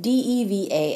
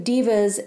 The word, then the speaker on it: deva: Not divas like a pop star, but D E V A S, diva maybe it's pronounced Deva, or celestial beings.